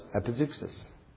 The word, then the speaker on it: epizeuxis: pronounced epizeuxis.